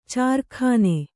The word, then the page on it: ಚಾರ್ಖಾನೆ: kannada; cārkhāne